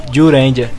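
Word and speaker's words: Zyuranger: Era Ziu Ranger. – Eles pronunciam Ju Ranger.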